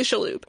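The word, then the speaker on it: Shalhoub: Like Tony Shaloub? – But it's spelled in this article with a C, but it's basically Shaloub.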